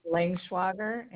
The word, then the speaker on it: Langschwager: Langschwager, and my zip code is 48626.